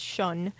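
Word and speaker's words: Ssion: Before that, Fool's Gold by Shun. It's S-S-I-O-N. They're pronounced Shun.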